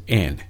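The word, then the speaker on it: en (ἐν): The very first word is on, and on is Greek number 1722, and the Greek pronunciation is en.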